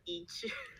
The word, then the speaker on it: 一曲: 对，而且除了哈曲黑曲，其实我们还有另外一个叫做一曲，二声的一就是那个一的那个一，就是。